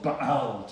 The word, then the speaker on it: Baal: It was called the worship of Baal, to give it the correct pronunciation, who's a fertility goddess.